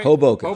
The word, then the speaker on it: Hoboken: Hoboken.